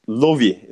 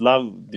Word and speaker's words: Love: Bir de nasıl okunduğunu Portekizcesine ben baktım, telaffuzu şeyine. Lovi, zaten Portekizce de aynı anlama geliyor aşk ama hep love diyor. Ben de love diyordum ama Lovi diye okunuyormuş.